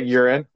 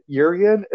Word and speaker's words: Yurian: So Yuren, is it, I always say Yuren, like piss, but I think it's Yurian. Yurian is the way you pronounce it.